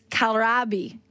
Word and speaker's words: kohlrabi: It is spelled K-O-H-L-R-A-B-I. I've been saying kohlrabi. Phonetically, that's how it looks. Carol says it is kohlrabi.